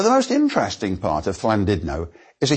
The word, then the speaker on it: Llandudno: English people pronounce its name Llandudno. However, the two L's at the beginning of the word should be pronounced fl, so try to pronounce it Llandudno.